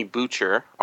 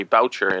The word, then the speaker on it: Boucher: Anthony Boucher, or sorry, Boucher is how it's pronounced, was the editor of, uh, get, not Galaxy, um, science fiction and fantasy magazine, magazine of science fiction mm-hmm.